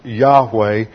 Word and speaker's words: Yahweh: See the yod and the H, Y-H-W-H. This is called the sacred tetragrammaton, or four letters, the sacred name of God, which is usually pronounced Yahweh. And at one time, the Old English, they transliterated the Y with a J, and they inserted the vowel points from Adonai, and it became Jehovah.